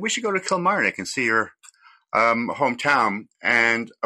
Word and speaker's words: Kilmarnock: How do you pronounce it? – Kilmarnock.